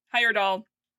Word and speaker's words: Heyerdahl: Heyerdahl. I keep mispronouncing his last name. I put the D in the wrong place.